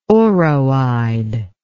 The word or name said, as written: oroide